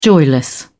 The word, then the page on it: joyless: British English